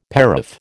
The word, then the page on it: paraph: American English